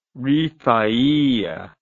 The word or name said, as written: rifaiya